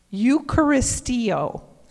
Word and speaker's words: Eucharisteo: And the Eucharist is spelled E U C H A R I S T E O. Eucharisteo.